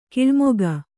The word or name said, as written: ಕಿಳ್ಮೊಗ